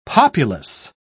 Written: populous

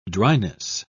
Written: dryness

